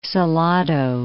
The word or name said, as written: Salado